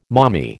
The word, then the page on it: mommy: American English